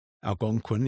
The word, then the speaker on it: Algonquin: And a lot of these uh, book clubs dedicated to other things, you know, having conversations at the Gonquin Hotel. Did I say that right? – Algonquin, yeah.